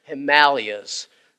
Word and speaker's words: Himalayas: Four guys who were pastors or trying to start churches in some of the remotest areas of the Himalayas, is the way it's supposed to be pronounced, I understand.